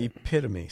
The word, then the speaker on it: epitome: Epitome. So it's epitome.